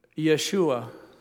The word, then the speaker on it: Yeshua: Jewish people would pronounce it Yeshua. And in ancient Greek, they pronounced it Jesus. And we would say Jesus.